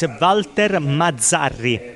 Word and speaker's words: Walter Mazzarri: First of all, for our listeners back in England, can you give us the authentic Italian pronunciation of his name? – For sure, it's Walter Mazzarri. – Walter Mazzarri.